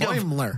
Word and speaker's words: Boimler: If without her, if you pair Mariner with Bulimer, I hope I said that right. – Boimler. Think Boimler. – Of Boing.